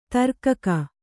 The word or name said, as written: ದೀರ್ಘಿಕೆ